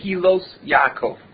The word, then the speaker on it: Gilas Yaakov: How do you say that? Gilas Yaakov.